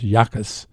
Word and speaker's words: Jacas: Although defensive end, I, uh, they're talking about Gabe Jacas, uh, J A C A S. I think it's pronounced Yakas and he's from Fort Pierce, and he's 260 pounds.